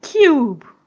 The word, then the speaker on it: cube: Another one is a word like cube. Cube. Cube. In spelling, there's no E. But the why pronouncing it? You say cube. You can hear that cube. There is E after K.